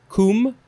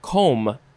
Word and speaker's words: combe: Next we have, I think you could pronounce it either coombe or combe, C-O-M-B-E, or you could spell it C-O-O-M-B-E, or no E at the end with two O's.